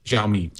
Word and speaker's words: Xiaomi: How do you say this Chinese Android manufacturer's name? Xiaomi? – Xiaomi.